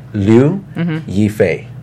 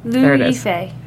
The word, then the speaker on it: Liu Yifei: Her name is pronounced Liu Yifei. – Okay. – Liu Yifei.